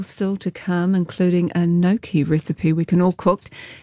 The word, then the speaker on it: gnocchi: I just googled it. Gnocchi, apparently. I think we've been saying gnocchi.